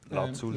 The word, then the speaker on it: Lazuli: Und wie spricht man den Titel aus? – Beach House, oh, Titel, Lazuli. Ähm, Lazuli.